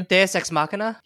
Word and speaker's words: deus ex machina: So the Dua Machina, whatever, maybe? The thing at the last minute that saves you? – Deus Ex Machina? – Yeah, I just totally butchered that.